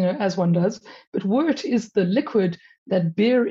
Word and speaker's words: wort: And the first one is wort, which is kind of a funny word because it's spelt W-O-R-T instead of W-E-R-T, but it's pronounced wort, not wart. And it's not a wart on your foot, it's wort.